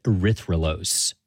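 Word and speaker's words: erythrulose: And they scanned it for radio signals of different molecules, which is how they found a four-carbon sugar called erythrolose. – I've heard of, like, glucose and fructose, but, like, how do you say that? – Erythrolose.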